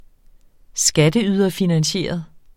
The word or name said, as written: skatteyderfinansieret